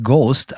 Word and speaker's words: GOST: Der Künstler nennt sich G-O-S-T. Wie spricht man das eigentlich aus? Einfach Ghost? – Ich spreche das dann einfach Ghost aus.